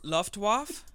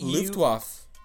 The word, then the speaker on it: Luftwaffe: L- Luftwaffe? That's not bad. Sure. Squadron. yeah. Uh, how would you say it? How do you say it? It's L yeah, Luftwaffe.